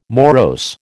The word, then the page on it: morose: American English